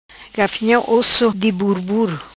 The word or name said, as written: Gafinha Osso di Burbur